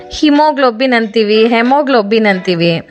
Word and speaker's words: haemoglobin: ಅದೇ ರೀತಿ ಈ ಹಿಮೋಗ್ಲೋಬಿನ್ ಕೂಡ, ಇದು ಆ್ಯಕ್ಚುಲಿ ನಾವು ಹೇಳಬೇಕಾದ್ದು ಹೆಮೋಗ್ಲೋಬಿನ್ ಅಂತ. ಇದರ ಸ್ಪೆಲ್ ಮಾಡ್ತೀನಿ ನೋಡಿ, HAEMOGLOBIN, ಹೆಮೋಗ್ಲೋಬಿನ್. ಎ ಇದೆ, ಶುರುವಲ್ಲೇ ಎ ಇದೆ, ಆದರೆ ಅದ್ರ ಉಚ್ಚಾರ ಆಗ್ತಾ ಇಲ್ಲ, ಕರೆಕ್ಟಾ?